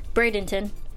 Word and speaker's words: Bradenton: for this episode, Bradenton, Florida.